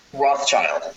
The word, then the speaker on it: Rothschild: Is it Rothschild or Rothschild? – Rothschild. The S is silent.